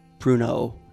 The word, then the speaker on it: Prunow: Prunow, just O. Like, you looked at it phonetically.